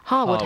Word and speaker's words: Harewood: We will see you at Harewood House, or as some people can pronounce it, Harwood House, Wednesday 28th.